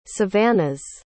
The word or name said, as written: savannahs